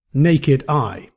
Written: naked eye

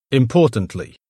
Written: importantly

